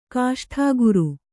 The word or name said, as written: ಗೋಷ್ಠಾಗಾರ